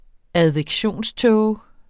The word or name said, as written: advektionståge